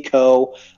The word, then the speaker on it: Koh: Co. Last name is spelled K-O-H.